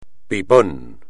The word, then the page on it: pipón: espagnol